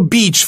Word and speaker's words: beach: 이걸 잘못 찾아가지고 B-E-A-C-H를 짧게 발음하시면 큰일 나요. – Yeah, right. Hit is very short. 네. Beach is very long.